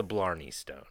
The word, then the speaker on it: Blarney Stone: One, it's the Blarney Stone.